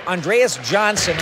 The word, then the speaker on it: Andreas Johnsson: But for the immediate future, for tonight's game against the Montreal Canadiens, we have Andreas Johnsson, and that's how he wants to say his name, along with Fabian Zetterlund coming into the lineup today.